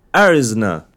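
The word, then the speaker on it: Arizona: مفيش حد يا جماعه مفيش حد في اريزونا بيقول اريزونا بيقولوا اريزنا اريزونا اريزونا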